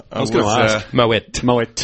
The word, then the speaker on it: Moët: Moët. – Always learning. – There's a little rhyme that we have. It's Moët if you're showing, it's Moët if you know it.